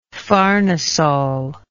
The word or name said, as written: farnesol